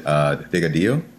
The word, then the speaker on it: Delgadillo: Is it Delgadillo? – Yep, Delgadillo, you got it.